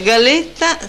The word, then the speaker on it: galetta: Dunque, galetta, si pronuncia tale quale, galetta,